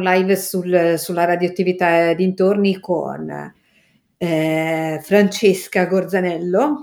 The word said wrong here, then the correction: Gorzanelli: Sì, aspettate che l'ho detto bene. Francesca Gorzanelli, chiedo scusa.